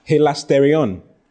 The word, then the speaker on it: hilasterion: Then the next word is hilasterion. Hilasterion. H-I, hilasterion is spelled H-I-L-A-S. T-E-R-I-O-N. H-I-L-A-S-T-E-R-I-O-N. Hilasterion.